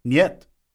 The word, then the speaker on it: Нет: How do you say that? – Нет. – Is that N-I-E-T-E? – N-Y-E-T. Нет. – N-Y-E-T.